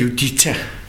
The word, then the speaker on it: Judita: Og der var en journalist, som hed Judith Machasova, hvis jeg ellers udtaler det rigtigt. – Ja, Judita. – Judita, ja, det er rigtigt, undskyld.